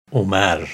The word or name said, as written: Omar